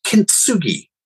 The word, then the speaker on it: kintsugi: You mentioned something else in your email, kintsugi. Have I pronounced it right? – Yes. So